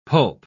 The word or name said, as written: pulp